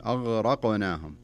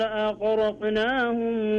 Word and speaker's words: أغرقناهم: لاحظ شوف الغين بعدين جاء بعدها حرف الراء بعدين جاء بعدها حرف القاف، فما نبغى ننطق الغين كأنها أو القاف كأنها غين، أغرقنا، لا خلها أغرق أغرق أقلها أغرقناهم.